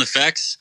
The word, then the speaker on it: Nofex: It's just yeah, it's like all we ever say, like when we first came up with it, is we were thinking of like the term like effects, like special effects, and it was like threw an N on it, like Nofex.